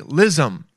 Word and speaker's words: lissom: Limber, nimble, lissome. Nope, that's something in your body. Lissome, right? How do you say it? Lissome. – Lissom. – Lissom. Yeah, lissom.